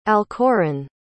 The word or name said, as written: alcoran